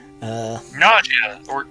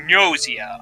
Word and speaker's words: Gnosia: Nausea. It's Gnosia. Not nausea, as in like you're sick. It's got a G in front of it. It's like pronounced like Gnostic. Gnosia, Among Us.